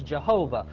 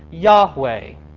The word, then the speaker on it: Yahweh: Some people say, I thought that was Jehovah. No, it's pronounced Yahweh.